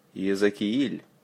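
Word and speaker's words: Иезекииль: Ezekiel. Accent on the second letter E. Kiel. Don't forget the soft L.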